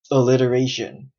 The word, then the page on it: alliteration: American English